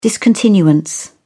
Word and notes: discontinuance: British English

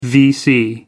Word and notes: vc: American English

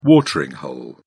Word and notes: watering hole: British English

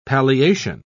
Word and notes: palliation: British English

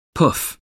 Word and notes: pouf: American English

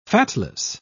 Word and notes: fatless: British English

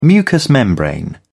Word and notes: mucous membrane: British English

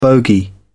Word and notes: bogey: British English

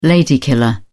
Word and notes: lady killer: British English

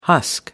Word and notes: husk: American English

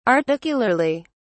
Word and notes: articularly: British English

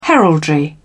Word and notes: heraldry: British English

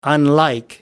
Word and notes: unlike: American English

